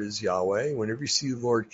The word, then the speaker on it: Yahweh: And it's pronounced Yahweh, YHWEH.